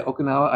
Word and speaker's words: Okinawa: It says Kadena Air Base, Okinawa, Japan. Yep. Am I saying that right? – Yeah, Okinawa. I've been there before.